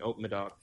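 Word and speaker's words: Haut-Médoc: Hoc Medoc is how you say it, or Haute Medoc? – That's a subregion, Haute Medoc.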